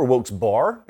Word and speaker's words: Wilkes-Barre: Wilkes-Barre, or Wilkes-Barre, as they pronounce it where I'm from, is tuned in Pennsylvania.